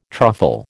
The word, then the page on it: truffle: American English